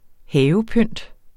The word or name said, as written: havepynt